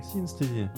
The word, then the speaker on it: синестезия: Там много, очень много э, эффекта синестезии Правильно же? С анестезией ударение. Анестезия. Синестезия. – Синестезия. – Да.